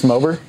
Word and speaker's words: MOBA: MOBA. – I think it's pronounced Mober.